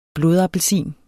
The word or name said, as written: blodappelsin